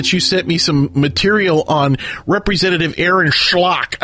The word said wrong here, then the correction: Schock: I mean, sorry, Aaron Schock.